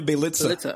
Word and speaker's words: Bjelica: I think it's a Pielka or whatever. But he's Nemanja Bjelica.